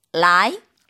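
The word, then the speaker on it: 라이 (来): 중국어에서 오다는요. 어떻게 발음할까요? – 정답은 2번이죠. 라이.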